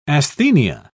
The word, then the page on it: asthenia: British English